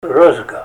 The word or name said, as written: rozga